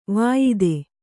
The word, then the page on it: ವಾಯಿದೆ: Kannada